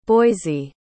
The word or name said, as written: boise